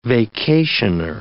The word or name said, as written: vacationer